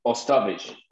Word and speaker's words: Ostovich: Ostovich.